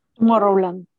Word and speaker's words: Tomorrowland: Tomorrowland.